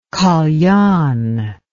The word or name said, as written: kalian